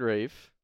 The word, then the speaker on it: Ralph: It's already wacky enough that his name is R-A-L-P-H and it's pronounced Rafe.